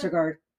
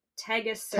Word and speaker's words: Tegaserod: Tetragard, Tegaserod.